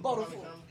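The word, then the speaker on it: Beau DaFooL: Bo, B-E-A-U, the fool, D-A-F-O-O, capital L. – Capital L. – Bo the fool.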